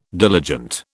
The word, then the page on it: diligent: British English